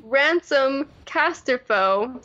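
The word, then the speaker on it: Ransolm Casterfo: So, Ransom Castorfo, silent L, emphasis on the second syllable, is a centrist, as opposed to Leia being a populist.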